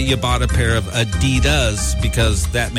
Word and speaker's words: Adidas: It's supposed to be Adidas, with a slight emphasis on the first syllable, not Adidas, because the man who created Adidas was Adi Dassler.